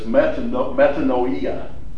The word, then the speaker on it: metanoia: Well, the word repentance in the Greek is, and it doesn't really matter, but it's metanoia is how they pronounce it.